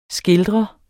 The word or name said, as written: skildrer